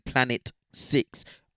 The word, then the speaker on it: Planet VI: But it's featuring Planet 6. But it's spelled Planet VI, like, you know, because Virgin Islands and all these things.